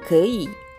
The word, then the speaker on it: 可以: でですね、えー、文字はですね、可能性の可という文字と、以上以下のいという文字を合わせて 2 文字で、可以、可以というふうに発音します。発音記号は最初の文字が KE 第 3 声、 2 つ目の文字が YI 第 3 声、で第 3 声が 2 つ続きますので前の第 3 声が第 2 声に変化します。